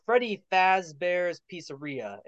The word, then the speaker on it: Freddy Fazbear's Pizzeria: Yeah, Freddy Fazbear's Pizzeria, if I'm saying that right. – That's right.